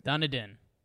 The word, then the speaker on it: Dunedin: Dundon, or Dundon, I don't know how you pronounce it. – Dunedin.